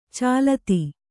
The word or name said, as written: ಚಾಲತಿ